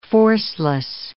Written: forceless

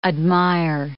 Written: admire

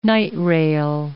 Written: night rail

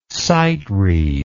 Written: sightread

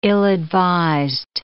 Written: ill-advised

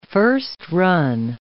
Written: first-run

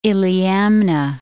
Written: Iliamna

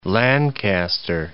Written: Lancaster

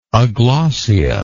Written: aglossate

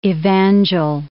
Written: evangel

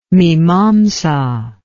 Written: mimamsa

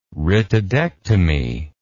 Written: rhytidectomy

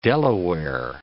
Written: Delaware

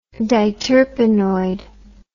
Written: diterpenoid

